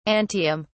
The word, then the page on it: antihum: British English